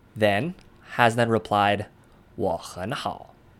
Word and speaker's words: 我很好: Since 我, 很, and 好 are all in the third tone, the first two of those would be pronounced as second tones in 我很好.